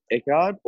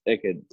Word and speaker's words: Eckerd: So, how do you pronounce it? Was it Eckard or Eckerd? – Eckerd.